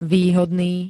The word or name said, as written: výhodný